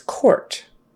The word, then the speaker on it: quart: And then we have the QU words where the U is just silent. For instance, quart.